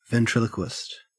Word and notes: ventriloquist: Australian English

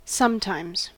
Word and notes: sometimes: American English

